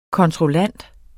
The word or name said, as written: kontrollant